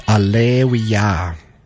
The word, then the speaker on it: Alewya: That's Alleluia. That's how you pronounce that. It's spelled A-L-E-W-Y-A.